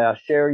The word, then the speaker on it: Asher: Title of the book is Coffee, The Fourth Wave, A Fresh Roasting Revolution by Asher Yaron, A-S-H-E-R. My last name is spelled Y-A-R-O-N.